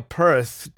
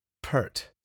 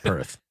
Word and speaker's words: Peart: Well, I know it's not Perth. It's got an ear sound, because that's what Mike Portnoy taught me. – Okay. – That Mike Portnoy has a fun story where he was saying his name wrong for years, and then finally Neil corrected him himself and says, you have to make sure you emphasize the ear sound.